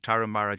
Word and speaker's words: Tarahumara: Think Tarahumara, just think of an Irish heroine in a terrible novel, and you're halfway there, the correct pronunciation.